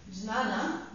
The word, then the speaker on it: jnana: Now, you write it like this, jnana, but you say jnana, this is like a silent J.